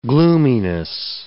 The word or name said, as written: gloominess